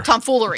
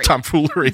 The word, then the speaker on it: tomfoolery: Tom thievery, is that how you say it? – Tomfoolery. Tomfoolery.